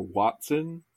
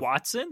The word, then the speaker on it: hoatzin: I've also heard Hoatzin or Watson. Yeah, there are a bunch of different pronunciations, but I'm going to go with Hoatzin? – Yes. – Like Watson and Holmes Watson? – More or less, yeah.